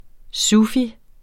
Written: sufi

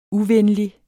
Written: uvenlig

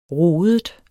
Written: rodet

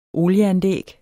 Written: olieanlæg

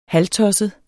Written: halvtosset

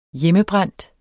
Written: hjemmebrændt

